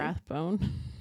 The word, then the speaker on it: Rathbone: Jackson Rathbone. Is that how you say his name? – Rathbone.